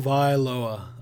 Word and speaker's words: Tagovailoa: I said Tagovailoa. – Tagovailoa.